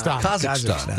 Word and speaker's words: Kazakhstan: How do you say it? – Kazakhstan. Thank you.